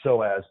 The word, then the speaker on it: psoas: We're saying psoas, but if our listeners are looking it up, it begins with a silent P. It's P-S-O-A-S, the psoas muscle.